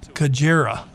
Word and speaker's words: Xigera: And the spelling is X-I-G-E-R-A. And they say the correct pronunciation is Kajera. I don't know how you get K-A from X-I.